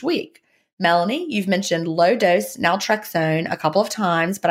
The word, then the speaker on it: naltrexone: The subject is question about naltrexone.